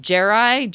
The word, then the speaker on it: Jerai: So I think it's pronounced Jerai, J E R A I.